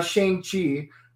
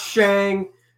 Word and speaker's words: Shang-Chi: It's not Shang. You would piss off the Asians mispronouncing their names. – Yep. – It is Shang-Chi.